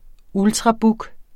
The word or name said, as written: ultrabook®